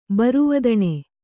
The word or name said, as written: ಬರಿವದನೆ